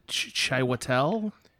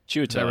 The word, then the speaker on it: Chiwetel: It's is it Chiwetel? – Chiwetel, right? I believe. – Chiwetel.